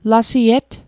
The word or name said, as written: lasiet